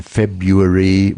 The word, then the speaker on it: February: And sometimes it's there because it's hard to pronounce, like the R sound in English, library, often library, right? And February rather than February.